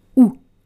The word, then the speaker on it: houx: So this word is challenging to read out loud because there are four letters, H-O-U-X, but you pronounce it OU. So the H is silent. Listen to me, native English speakers. The H is silent. And the X is silent as well. H-O-U-X, du OU. Du ou. The next word is emmener. Emmener. E-M-M-E-N-E-R.